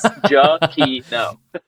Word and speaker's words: Giacchino: I went to his Twitter, and in his Twitter, he has the pronunciation as the pronunciation. – It's Giacchino.